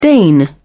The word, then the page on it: deign: British English